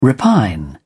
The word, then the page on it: repine: British English